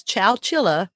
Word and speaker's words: Chowchilla: On July 15th, 1976, in Chowchilla, and I did look that up to see how you say it exactly. It is Chowchilla. Not chinchilla.